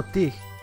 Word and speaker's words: tige: La palabra tija viene de Francia, donde el término tige, T-I-G-E, recuerda que sin acento no se dice la E final, se usa para nombrar un tallo o varilla.